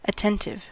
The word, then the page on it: aufmerksam: /ˈaʊ̯fˌmɛʁkzaːm/; [ˈʔaʊ̯fˌmɛɐ̯kzaːm]